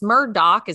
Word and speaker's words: Murdaugh: It's Murdoch is how you pronounce it, but it's Murdaugh.